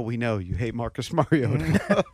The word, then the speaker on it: Mariota: Marcus Mariota. – It's so funny how everyone's taking these quarterback rankings so personally. – At least I pronounce his name correctly.